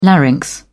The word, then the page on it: larynx: British English